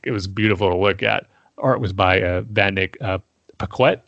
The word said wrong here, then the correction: Yannick Paquette: I'm probably butchering the name. But Yannick Paquette. – Yeah, that sounds a lot better than what I did.